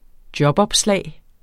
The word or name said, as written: jobopslag